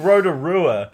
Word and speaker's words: Rotorua: It's fucking Rotorua. Okay. There's no L's, even if you are saying it properly.